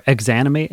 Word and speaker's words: Exanimate: There's a new EP or album, I can't remember, from this local Detroit band called Examinate. They're super heavy and super good. – Cool. I don't know. – Or Exanimate is how you say their name. It's Exanimate.